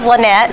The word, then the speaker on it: LynEtte: But it is LynEtte, so you got the name correct, but my Instagram is Highways Hope and Homemade Cookies.